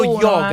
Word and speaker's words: yoga: Allora, viva le gnoghe di tutta Italia. Le yoga, scusa, ho sbagliato. Andiamo avanti con la musica, scusate, ho sbagliato io la pronuncia. – Viva lo yoga.